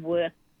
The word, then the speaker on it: Wuurrk: Wayapa meaning connect, and work, which is work is spelled Wuurrk, not work as in Work.